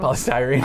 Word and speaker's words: polystyrene: polyestrin products. Council members spoke to the media ahead of the vote for that important ban. What, did I say it wrong? – Polystyrene.